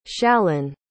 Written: shalin

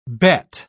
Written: Beth

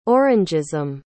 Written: orangeism

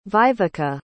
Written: viveka

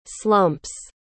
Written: slumps

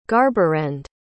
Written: garberend

